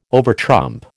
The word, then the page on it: overtrump: American English